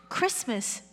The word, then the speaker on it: Christmas: Of course it's Christmas, but we don't pronounce the T inside. – 그리스도의 날입니다. – And so we just say Christmas.